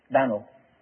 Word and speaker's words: Dan'l: Now, the Hebrew word Daniel has three syllables, Daniel. The English word has at least two, Daniel. But in those days, the American had just one. I'm not joking. Have you ever heard of Dan'l Boone, Dan'l Webster? In those days, they spelt the name D-A-N apostrophe L. They pronounced it in one syllable, Dan'l.